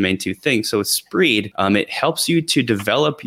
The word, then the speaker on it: Spreed: It's an extension called Spreed, which is tough to say, so I'm going to spell it also. S-P-R-E-E-D, Spreed. It's a sort of like a combination of the two words speed read, because that's what it's for.